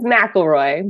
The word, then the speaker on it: McElroy: Little bit, but it was pretty good compared to some people. It's McElroy, but super close.